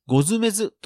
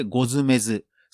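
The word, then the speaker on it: 牛頭馬頭: ゴズメズです。ポッドキャスト配信開始を TikTok で告知ほか。 – 今週はあるポッドキャストが人気という話題をお届けします。そのポッドキャストは、ゴズメズですという番組です。ゴズメズというのは漢字で牛の頭、馬の頭と書いてゴズメズ、そして死を意味するデスゲームなどのあのデスですね。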